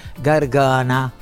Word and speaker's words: Gargana: Sì, l'album, guarda caso, si chiama, sembra Gargana, ma è una parola sì, dialettale, che si pronuncia Gargana, che vuol dire vociaccia, poi insomma, perché io fin da bambina ero un mostro, perché avevo la voce che ho adesso.